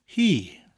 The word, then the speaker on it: hi: The word for she in Welsh is he, spelled H-I, but pronounced like the English word he.